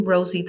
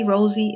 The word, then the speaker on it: Rosey: And feel free to follow me on my social media platforms such as Facebook, TikTok, and Instagram at I Am Rosie the Boss Lady. Rosie is spelled R-O-S-E-Y.